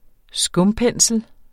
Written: skumpensel

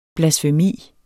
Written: blasfemi

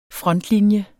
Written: frontlinje

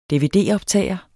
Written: dvd-optager

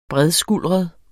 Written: bredskuldret

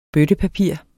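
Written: BØTTEPAPIR

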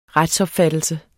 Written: retsopfattelse

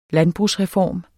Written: landbrugsreform

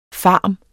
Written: FARMENES

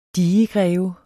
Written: digegreve

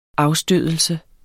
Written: afstødelse

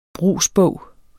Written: brugsbog

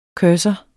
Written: CURSOREN

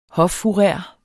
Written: hoffourer

